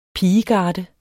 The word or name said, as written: pigegarde